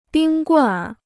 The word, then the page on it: 冰棍儿: bīng gùnr